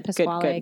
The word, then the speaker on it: Pasquale: You don't say the E. – Stephen Pasquale. Okay, yeah. good. – I said Pasquale because yeah, I got scared that I was pronouncing it wrong.